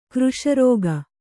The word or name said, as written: ಕೃಶರಾಗ